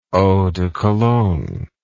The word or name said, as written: eau de cologne